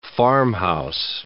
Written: farmhouse

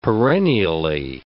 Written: perennially

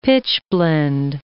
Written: pitchblende

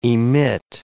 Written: emit

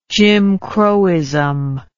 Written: jimcrowism

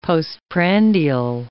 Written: postprandial

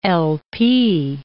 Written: LP

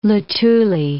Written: Luthuli

